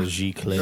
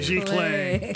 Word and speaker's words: giclée: A glissé. – There we go. – No, it's a giclée. – A giclée.